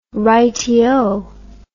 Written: rightyo

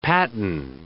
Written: patten

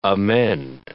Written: amend

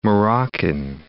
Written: Moroccan

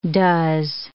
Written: does

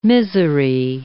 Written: misery

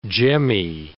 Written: jemmy

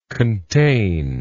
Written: containedly

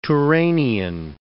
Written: Turanian

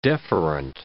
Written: deferent

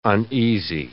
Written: uneasy

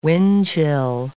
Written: windchill

